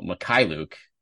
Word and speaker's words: Mykhailiuk: So the Basketball Reference pronunciation, first of all, Mykhailiuk is the emphasis with the emphasis on the K in the middle there, which is a real curveball when you look at it, you look at the last name.